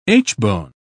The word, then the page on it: aitchbone: British English